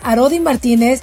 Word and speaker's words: Arodi Martínez: el doctor Arodi Martínez, obviamente psicólogo y experto en conducta humana.